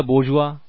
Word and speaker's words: bourgeois: B-O-U-R-G-E-O-I-S. Bourgeois. This is a French word, French pronunciation hai iski. That is why the spelling is somewhat strange than the pronunciation. But just get the spelling right, that is enough.